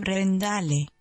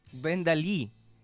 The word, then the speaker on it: Brendalí: Si tengo una hija, le pondré Brendale. – Brendalí, no Brendale. Bueno, como no tiene acento en la máquina rusa, vive en un mundo sin acentos.